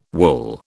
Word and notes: wool: British English

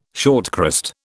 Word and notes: shortcrust: British English

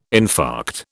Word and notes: infarct: British English

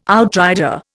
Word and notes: outrider: Indian

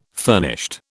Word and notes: furnished: British English

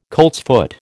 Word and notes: coltsfoot: American English

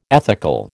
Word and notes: ethical: American English

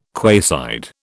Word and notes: quayside: British English

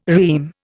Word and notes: REME: Indian

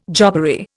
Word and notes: jobbery: Indian

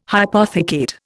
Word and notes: hypothecate: Indian

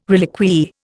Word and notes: reliquiae: Indian

